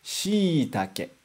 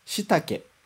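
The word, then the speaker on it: shiitake: A gente junta os dois i's e faz um i mais prolongado. Shi, itake. Deu para ouvir? Shi, itake. Tá. E se não tivesse esse i, como que ficaria a pronúncia? Ficaria assim. Shitake. Shitake. Viu que ficou muito diferente? Então, a gente tem que prolongar esse som por causa desse i.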